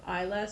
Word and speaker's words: Islay: Isla, spelled like Islay, Islay, yeah. – But it's pronounced Isla, and that there is a distillery there called Brugh Ladig, which has the Victorian equipment extant and working, and they use it.